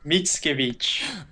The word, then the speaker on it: Mickiewicz: Mickiewicz.